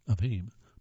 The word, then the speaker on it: heme: Number 11, Impossible Foods spent years developing a molecule called heme, which, I'm sorry, that's pronounced heme. It's H-E-M-E, but it's pronounced heme. I guess it's like the things on computers called memes, which gives its product a bloody red appearance and beefy taste.